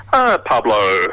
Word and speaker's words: Pablo: Pablo. – Pablo, spell it for us. Spell it for us. – First off. – P-A-B-L-O.